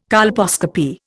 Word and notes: colposcopy: Indian

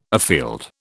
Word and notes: afield: British English

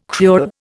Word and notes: cruel: Indian

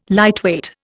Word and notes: lightweight: Indian

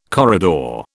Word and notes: corridor: British English

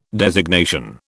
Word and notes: designation: British English

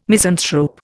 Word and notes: misanthrope: Indian

